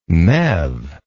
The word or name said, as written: MeV